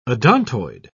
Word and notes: odontoid: British English

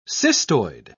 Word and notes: cystoid: British English

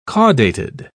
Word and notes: caudated: British English

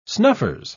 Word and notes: snuffers: British English

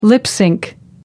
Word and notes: lip synch: American English

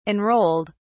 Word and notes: enrolled: British English

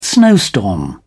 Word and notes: snowstorm: English; British English

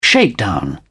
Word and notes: shakedown: British English

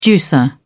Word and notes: juicer: British English

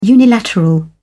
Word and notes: unilateral: British English

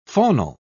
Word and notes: faunal: British English

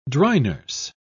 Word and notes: dry-nurse: British English

